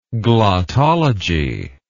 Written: glottology

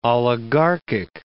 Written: oligarchic

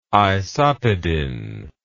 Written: isopedin

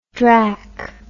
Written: drack

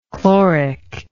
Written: chloric

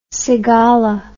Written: cigala

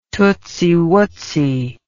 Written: tootsy wootsy

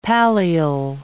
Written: pallial

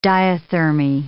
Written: diathermy

